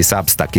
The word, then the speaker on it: Substack: Curi con Selvaggio Lucarelli la newsletter di Substack Italia, detto bene?